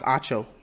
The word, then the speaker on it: Acho: To another episode of Uncomfortable Conversations with Emmanuel Acho. Once again, okay, so his last name is pronounced Acho.